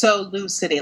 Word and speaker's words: SoLouCity: I think it's Solo City, like Louis, St. Louis.